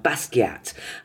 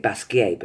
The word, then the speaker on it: Basquiat: I felt quite emotional when I went into the theatre, because it was a play about Andy Warhol and Jean-Michel Basquiat. Up until I saw the play, I was pronouncing it Basquiat, but apparently it's Basquiat, and who's a young black, very influential painter in the 80s in New York, and they did a collaboration together.